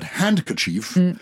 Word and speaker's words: handkerchief: It's an interesting one because handkerchief, hanky is H-A-N-K-I-E. – Yes. – But handkerchief. – Kerchief, your hand. – Yeah. But you don't pronounce the D, so it's a silent D.